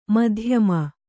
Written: ಮಧ್ಯಮ

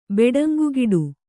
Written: ಬೆಡಂಗುಗಿಡು

